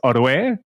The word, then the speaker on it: Orue: It's close enough. It actually is a tricky name even in Spanish. I'm Alex Orue.